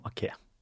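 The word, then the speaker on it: Aké: Eh, og riktig uttale er Nathan Ake. Nathan Ake.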